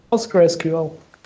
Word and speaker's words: PostgreSQL: Do you say PostGRE or PostgreSQL? Or how do you pronounce it? PostgreSQL.